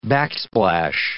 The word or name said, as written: backsplash